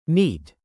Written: NEET

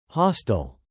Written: hostile

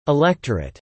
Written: electorate

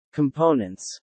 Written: components

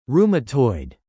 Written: rheumatoid

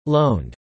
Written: loaned

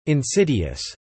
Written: insidious

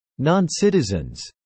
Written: noncitizens

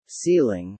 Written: ceiling

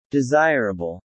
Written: desirable

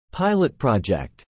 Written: Pilot project

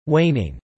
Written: waning

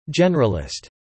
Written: generalist

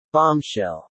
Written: bombshell

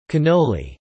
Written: cannoli

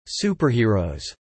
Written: Superheroes

